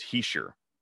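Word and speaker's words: Hischier: And he said that the NHL pronunciation guide, along with his production contact, said it's Heischer. That's what the sheet said.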